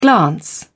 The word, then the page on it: glance: British English